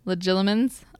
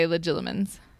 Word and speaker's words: legilimens: is that he is great at legilimens. I always say that wrong. Did I say that right? – Yeah, I think.